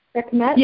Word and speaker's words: Sekhmet: I didn't know anything about, and I'm going to butcher the way it's pronounced here, the Egyptian lioness Sekhmet.